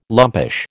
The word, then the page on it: lumpish: American English